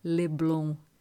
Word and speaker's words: Leblon: Bom de ouvir e dizer, Leblon. A primeira sílaba se eleva, anel breve, e desaparece, logo que a outra, em onda lenta e dilatada, se desabotoa.